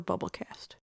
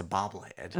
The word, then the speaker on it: bobblehead: Bubble cast? Like a bubble head? It's a bobblehead. – It's a bobblehead, yeah. Yeah. – I've been saying that wrong for a long time, and it just clicked.